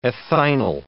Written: ethynyl

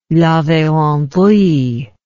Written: laverendrye

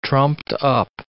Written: trumped-up

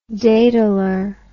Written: dataller

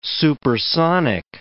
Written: supersonic

